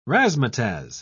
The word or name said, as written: razzmatazz